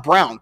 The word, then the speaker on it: Braun: It's pronounced Brown. Christian, it's weird. It's spelled like that. Yeah, it's pronounced Brown. Christian Braun.